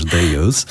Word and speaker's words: dEUS: ou com a pronúncia correta dos belgas Deus, ao nosso país.